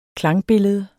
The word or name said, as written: klangbillede